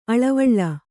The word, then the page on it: ಅಳ್ಳೆವಿಳ್ಳೆ: kannada